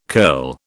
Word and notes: curl: British English

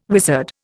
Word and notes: wizard: Indian

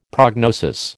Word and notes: prognosis: American English

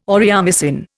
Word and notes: aureomycin: Indian

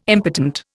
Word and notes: impotent: Indian

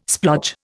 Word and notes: splodge: Indian

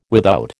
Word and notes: without: American English

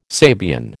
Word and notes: Sabian: American English

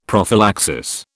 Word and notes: prophylaxis: British English

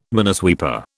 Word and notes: minesweeper: British English

